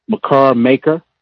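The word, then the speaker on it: Makur Maker: And excuse me if I mispronounce his name, McCarr Maker.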